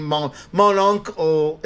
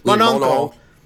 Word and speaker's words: Mon Oncle: And the Tati comparisons, like if you've ever seen Mon Oncle is a, I'm pronouncing that weird. Mon Oncle. Yeah, there you go.